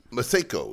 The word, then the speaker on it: Masego: Masego, yeah. – Masego.